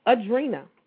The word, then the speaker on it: Adrienna: In English, my mom pronounces it Adrina. So it's a long Adrina. – E. Yep. – Adrina.